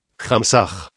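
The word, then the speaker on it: Hamsa: Muchas veces les he explicado a ustedes cómo se trabaja con el mantra Mamsa que se pronuncia así. Hamsah.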